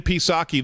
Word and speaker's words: Psaki: And Jen Psaki, the P is silent, is asked in the briefing room the other day about this.